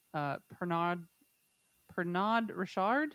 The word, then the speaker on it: Pernod Ricard: The Burrough family sold the company to Whitbread in 1987, and in 2005, Beefeater was acquired by the French Pernod Ricard? Is that how you say it? – Sure. – Pernod. – Sure. I keep wanting to say Ricard, and I was like, there's no way that's right. – Captain Ricard.